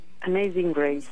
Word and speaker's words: Amazing Grace: Bueno, esto es Amazing Grace, ¿no? ¿Lo digo bien? – Amazing Grace, Joan Baez.